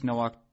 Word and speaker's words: Nowak: I believe you pronounce it Chris Nowak, N-O-W-A-K.